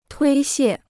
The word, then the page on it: 推卸: tuī xiè